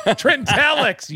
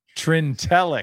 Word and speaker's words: Trintellix: I took some beta blockers, a couple of Trintellixes. – Trintellix, you bastard. Say it right. – What's it called? Sorry. – Trintellix.